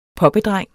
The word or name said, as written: poppedreng